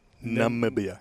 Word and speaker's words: Namibia: Say it properly. Namibia. There we go.